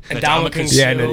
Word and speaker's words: Ndamukong Suh: Ndamukong Suh. I say that right? – Ndamukong Suh.